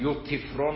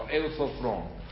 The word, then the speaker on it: اوسفرون: خوب دقت کنید، در اوسفرون، اوسفرون یه جور دیگه تلفظ نکنید، یوتیفرون رو نمیدونم چه از این حرفا، اوسفرون.